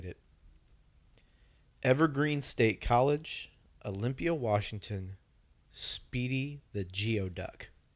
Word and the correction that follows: Geoduck: Also known as, I mispronounced it. Sorry. Gooey Duck. – Yes. – Is what it's technically called. – It's spelled Geoduck. – It's spelled Geoduck, but it is Gooey Duck.